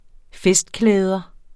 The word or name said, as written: festklæder